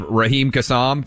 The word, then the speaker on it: Raheem Kassam: Raheem Kassam, did I get that right? – You nailed that one.